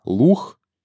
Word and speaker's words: лук: А в данном случае надо учитывать, что поэт произносил не «лук», а «лух». Да, и, соответственно, «лух» и «петух» здесь абсолютно точная рифма.